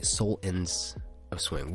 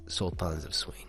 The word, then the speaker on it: Sultans of Swing: In the song, they're saying soul ends of swing. We're the soul ends of swing. But I like, sultans of swing. That's how they say it. That's how you gotta say it.